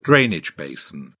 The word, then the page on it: drainage basin: British English